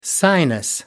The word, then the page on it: sinus: American English